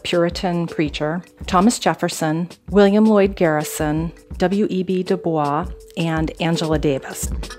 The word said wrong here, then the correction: Du Bois: Sorry to correct you, but I think it's Du Bois, W.E.B. Du Bois. – That's how he pronounced it.